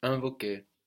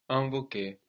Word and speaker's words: invoquer: So we noticed there how the IN gave us un, invoquer. And this is because, again, the N on the end of the syllable is getting swallowed into the vowel, just like what we saw with confirmer.